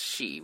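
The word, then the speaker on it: shi (四): And sometimes, you know, we don't like the in Asian in Japan that we don't like the number four, but because four is pronounced as shi, which is the same as death.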